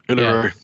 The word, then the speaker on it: Uluru: I got to eat some sausage and I got to go eat outside with full view of Uluru. Did I say that right? – Uluru.